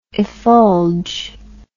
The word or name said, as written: effulge